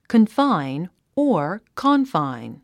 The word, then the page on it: confine: kənfáin; コンファイン